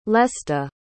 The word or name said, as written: lesta